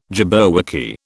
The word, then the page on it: jabberwocky: British English